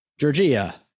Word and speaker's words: Giorgia: Giorgio Meloni. – She looks like a Maria. – I don't think it's Giorgio. Are you saying Giorgio? – Giorgia. – Giorgia Meloni. Okay.